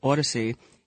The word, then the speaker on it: Audacy: Well, so the company, I think it's pronounced Audacy, and their Chapter 11 bankruptcy filing, so they submitted it to the court.